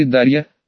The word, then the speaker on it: Дарья: Девочку назвали Дарья, с ударением на последнюю букву.